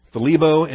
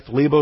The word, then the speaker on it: thlibo: All right, thlebo is the verb, thlipsis is the noun. Thlebo, hard to pronounce, T-H-L. That's an awkward thl. We don't have any English words to start with. Thlebo. All right. T-H-L-I-B-O.